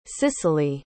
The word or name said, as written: sicily